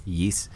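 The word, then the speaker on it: Ys: It's Y-S if you look at it, but it's called pronounced Yeast.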